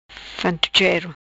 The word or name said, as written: fantuchero